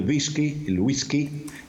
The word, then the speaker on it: whisky: E quindi il whisky, noi diciamo whisky, naturalmente. Però quando è entrato in italiano questa parola ha prevalso la pronuncia toscana che ha pronunciato wi come V.